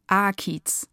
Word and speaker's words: Akiz: Akiz, aber ich sag's immer falsch. Also meine Agentin sagt, ich sage immer, also Betonung auf A. Können Sie das? – Akiz.